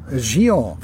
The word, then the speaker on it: Gion: Gion. – Versucht es mal mit Gion, haben sie geschrieben.